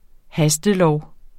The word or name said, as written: hastelov